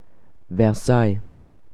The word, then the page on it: Versailles: German